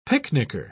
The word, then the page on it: picnicker: British English